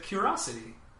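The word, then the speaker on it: Qriosity: It's spelled Sony Music, the letter Q, Riosity. – Curiosity. So you have to say Curiosity.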